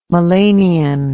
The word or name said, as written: melanion